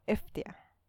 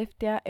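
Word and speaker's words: Ftía: Se escribe F-T-I-A, ¿Cómo se pronuncia eso? Eftia. Eftia, vamos a decirle.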